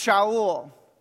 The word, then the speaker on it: Shaul: Saul's name in Hebrew is pronounced Shaul. Shaul comes from the Hebrew word sha'a, which means to ask.